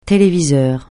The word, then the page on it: téléviseur: francais